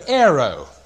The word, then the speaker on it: airo: And the word is the word aero. I better spell it for you because you're going to spell it wrong otherwise. It's not a chocolate bar. A-I-R-O. And it's the word aero. A-I-R-O.